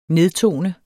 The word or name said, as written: nedtone